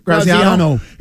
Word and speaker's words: Graziano: Dan Graziani. Is that how you say it? – Graziano.